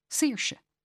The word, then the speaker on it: Saoirse: Which brings me to the thorny matter of how to pronounce any name with the letters A-O-I in it. Aoife, for example. Saoirse. Caoimhe. Naoise. The A-O-I sound in Irish is pronounced like a double E in English, and people have got used to that.